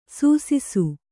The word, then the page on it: ಸಾಸಿಸು: sāsisu